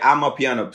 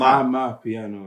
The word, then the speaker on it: Amapiano: So, oh yeah, I'm a piano. See, the people are saying I'm piano, but it's yeah, ama. ama piano.